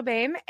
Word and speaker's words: Boehm: All right, I am so excited to introduce our guest today, Rachel Boehm. Is that how you say your last name? – You nailed it. – Awesome. Rachel Boehm.